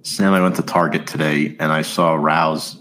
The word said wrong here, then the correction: Rao's: That's how you say it, right? Rouse? The tomato sauce brand? – Rao's. – Pardon. I'm not very up to date with my names, it seems. But Rao's, yeah.